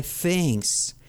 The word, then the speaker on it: things: These are my things. These are my things. Cosas en inglés digo things y pronuncio como una Z en español.